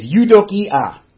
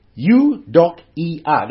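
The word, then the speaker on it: eudokia (εὐδοκία): It's eudokia. Eudokia. That's how it's pronounced.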